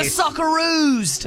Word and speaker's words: Socceroos: And go the Socceroos.